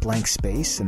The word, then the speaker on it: BLNKSPC: Jones, Cookies, I don't know how to say this, so I'm going to spell it out, STLN DRMS, Close to Sleep, Blank Space, and that's spelled BLNKSPC.